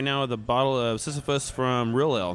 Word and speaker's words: Sisyphus: So for those of you who aren't familiar with Sisyphus, I did say Sisyphus. It's a Greek mythology, so we're clear on the pronunciation there.